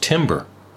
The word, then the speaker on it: timbre: Timbre is actually spelled T-I-M-B-R-E. Mm-hmm. So it you might think that it's spelled, or it's pronounced timbre, but it's actually timbre.